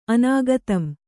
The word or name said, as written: ಅನಾಗತಂ